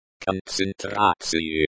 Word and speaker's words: концентрацию: The stress in сохраняйте falls on the third syllable. The stress in поддерживайте falls on the third syllable. The stress in концентрацию falls on the third syllable.